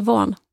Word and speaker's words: Vaughan: Jag ska kanske säga Van. Van, inga G ska höras.